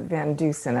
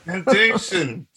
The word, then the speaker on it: Van Deusen: Yeah, that well, we actually pronounce it Van Dusen up here. – Van Dusen.